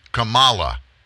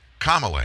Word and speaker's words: Kamala: She is not Kamala Harris. She makes it very clear, and she doesn't hesitate to stop somebody and correct them for her name's pronunciation. She is Kamala Harris, Senator Kamala Harris, Vice Presidential Candidate, Senator Kamala Harris.